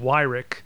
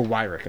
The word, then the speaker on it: Weyrich: I think his last name is pronounced Weyrich. – Oh, Weyrich. Okay.